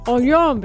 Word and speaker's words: oignon: And yes, that is how you say it in French. Oignon, baby. Oignon.